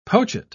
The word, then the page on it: pochette: British English